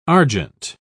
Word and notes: argent: British English

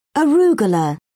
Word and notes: arugula: British English

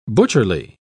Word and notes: butcherly: British English

